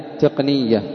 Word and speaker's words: تقنية: قال قولهم تكنولوجيا أي تقنية على وزن علمية، وهي مصدر صناعي من التقن بوزن العلم، والتقن الرجل الذي يتقن عمله. تقنية بمعنى مأخوذة من التقن، وهو الرجل الذي يتقن عمله. وما شاع من نطقها بوزن كلمة الأدبية أو بوزن كلمة التربية، فقال فيها التقنية والتقنية، فهو خطأ.